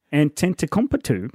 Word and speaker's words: Antetokounmpo: How do you say it? – Antetokounmpo. If you're Basil. Giannis Antetokounmpo.